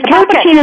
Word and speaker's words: Capuchin: But Casey, and I hope I have to ask if I'm pronouncing this right, Capuchin? – But it's Capuchin is also a pronunciation that people use.